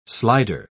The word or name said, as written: slider